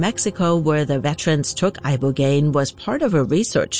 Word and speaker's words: Ibogaine: Ibogaine. Remember the name. Is that pronounced relatively properly, would yes.